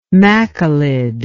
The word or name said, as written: machilid